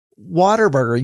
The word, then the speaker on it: Whataburger: Maybe it was someone else that said that retweeted Whataburger telling someone that you pronounce it Whataburger. Yes, that is correct. They spelled it out, Waterburger.